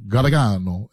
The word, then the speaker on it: Gargano: It's supposed to be, like, the old world says it, like, Gargano. Gargano is how you say it.